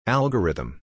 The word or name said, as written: Algorithm